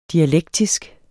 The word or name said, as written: dialektisk